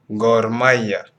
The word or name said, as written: Gor Mahia